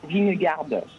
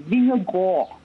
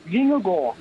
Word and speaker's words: Vingegaard: C'est pas Vingegaard, c'est Vingegaard je, vais vous apprendre à prononcer son nom correctement. Vingegaard.